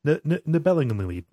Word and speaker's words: Nibelungenlied: It's Nibelungenlied. Nibelungenlied, yeah.